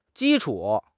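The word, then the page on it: ji1 chu3: tones 1, 3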